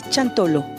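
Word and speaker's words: Xantolo: Chantolo se pronuncia chantolo.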